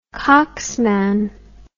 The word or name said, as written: cocksman